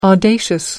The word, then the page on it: audacious: American English